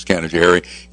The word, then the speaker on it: Canajoharie: I mean, that's what we say up here is Canajoharie. You had it a little... – I had to rhyme it with safari, so I didn't know how to do it exactly. – Yeah, it was Canajoharie. But in all honesty, you're using what we pretend to be, or we think might be, the Mohawk pronunciation,